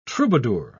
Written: troubadour

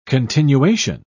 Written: continuation

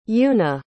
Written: yuna

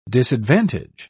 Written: disadvantage

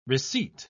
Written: receipt

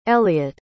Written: Elliot

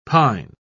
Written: pine

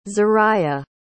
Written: zarriah

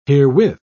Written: herewith

